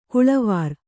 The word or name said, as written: ಕೊಳವೇರು